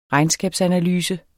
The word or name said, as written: regnskabsanalyse